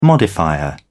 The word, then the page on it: modifier: British English